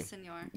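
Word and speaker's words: Villaseñor: Villasenor.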